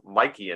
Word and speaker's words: Mikey: Mickey and Nikki, or as correctly pronounced, Mikey and Nikki, directed by Elaine May.